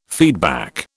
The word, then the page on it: feedback: British English